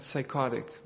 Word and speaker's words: psychotic: Yeah, psychotic. Very good. They'll do. Psychotic, yeah. Just in English, they drop the P. They drop the first P. So it's psychotic, but it's spelled.